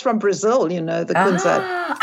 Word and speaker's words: kunzite: I think you pronounce kunzite. – That's right. – Oh, kunzite.